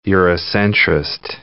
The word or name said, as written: Eurocentrist